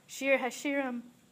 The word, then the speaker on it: Shir HaShirim: Shir HaShirim? – Yes.